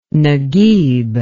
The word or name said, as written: naguib